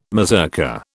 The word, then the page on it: mazurka: British English